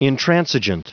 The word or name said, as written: intransigent